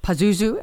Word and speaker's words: Pazuzu: who's the face of the demon Pazuzu, am I pronouncing that correctly, will be part of the Q&A.